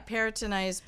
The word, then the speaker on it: peritonitis: Peritonitis? – Yeah, peritonitis, but it's...